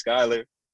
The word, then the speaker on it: Schuyler: It's spelled SCHUYPOD, kind of like the spelling of my name, because it's spelled SCHUYLER.